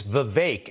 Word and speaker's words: Vivek: And he says, it's Vivek. And a lot of people say it the way you just said it, but it's Vivek, and I got that from him.